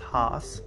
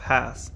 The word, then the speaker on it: Hass: And while I'm not an expert in avocado names, I do pronounce the avocado Hass, H-A-S-S, as Hass. According to the Hass Avocado Naming Foundation, the name rhymes with pass. Typically, I would have thought that it was pronounced Haas, but that is incorrect.